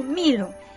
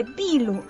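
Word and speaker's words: Bilu (秘鲁): Entonces, también me pasó de que una vez una joven me dice, Milu, los chinos dicen a Perú Milu, pero Milo. Vino otra joven y le dice a ella, no se dice Milu, se dice Bilu.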